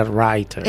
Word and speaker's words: Reiter: Se escribe Reiter, se pronuncia Reiter.